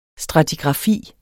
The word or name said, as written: stratigrafi